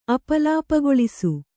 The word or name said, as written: ಅಪಲಾಪಗೊಳಿಸು